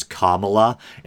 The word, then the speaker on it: Kamala: And I'm kind of self-conscious here because I keep saying Kamala, but people are now saying the proper pronunciation is Kamala, and this is going to lead me into the next story.